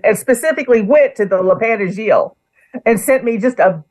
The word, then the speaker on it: Lapin Agile: Picasso at the Le Pont Argile. Is that what? How's that? – That was very good and very close. Le Pont Argile, which is the name of a bar in Paris.